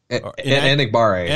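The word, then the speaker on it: Enagbare: The bottom five, Zach Tom, a guard out of Wake Forest, Kingsley and Engabear? – Enigbare. – Enigbare.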